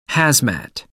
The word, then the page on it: hazmat: American English